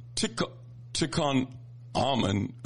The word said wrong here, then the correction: Tikkun Olam: It's T-I-K-K-U-N. – Oh. – Olam Initiative. Tikkun Olam Initiative.